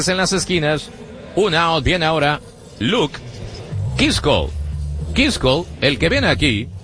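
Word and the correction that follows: Keaschall: Viene a batear de noveno bat Kichel. En la pronunciación de los mellizos, Kichel.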